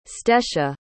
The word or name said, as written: stesha